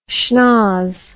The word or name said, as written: shnoz